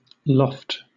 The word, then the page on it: loft: British English